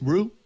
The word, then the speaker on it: Rioux: 15-year-old Oliver Rue? R-I-O-U-X? Rue? – Sure. – Is that how you say it?